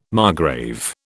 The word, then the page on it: margrave: British English